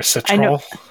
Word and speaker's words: Citroën: Is that how you pronounce that? I don't know. – Sure. Citroën.